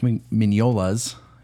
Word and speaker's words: Mignola: Now, Dark Horse is probably most famous for Mike Mignola's. His name is hard to pronounce sometimes. Mignola.